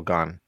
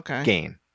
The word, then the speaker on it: Gane: It was Volkov against the winner of Rosenstrike and Cyril Gane. Gane. I'm always going to say his name wrong because I've heard it several ways, but I think it's Cyril Gane.